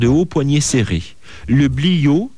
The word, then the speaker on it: bliaud: Alors les tuniques, chins et bliots. Chins, ça s'écrit C-H-A-I-N-S-E et bliot, B-L-I-A-U-D. Depuis le Xe siècle, les deux pièces principales de l'habillement des hommes et des femmes sont le chinze et le bliot.